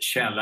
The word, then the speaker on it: Kjell: Kjell Enhager. And K-J-E-L-L is pronounced Shell.